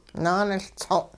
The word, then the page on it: naaniLtsong': hupa